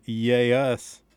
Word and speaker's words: Yay Us: new stuff from the Grimly Pleased and Yay Us. That's spelled Y-A-Y-U-S, so you know, I didn't throw my southern accent in there at you.